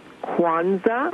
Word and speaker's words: Kwanzaa: I'd like to first of all tell your guest that it is pronounced Kwanzaa. – Mm-hmm. – It is spelled Kwanza.